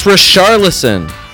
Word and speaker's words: Richarlison: The fact is, the digraph, the combination of the letters CH in Portuguese is pronounced like Shadow and DJ Shadow. A song by DJ Shadow. – Like a Shasteria? – Or Sheeran, as in Ed Sheeran. I'm not going to keep going. It's Richarlison.